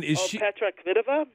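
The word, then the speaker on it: Petra Kvitová: Going in, Petra Kvitova? – Thank you.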